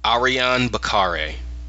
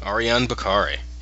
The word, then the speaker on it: Ariyon Bakare: A R I Y O N. So maybe it's, it's pronounced different than Ariane, but I'm pretty sure Bakare is Bakare. Yeah, Ariyon Bakare.